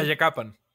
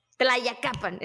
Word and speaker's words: Tlayacapan: y en el estado de Morelos en Tlayacapan. no sé si lo pronuncié bien. Tlayacapan. – Tlayacapan, exactamente.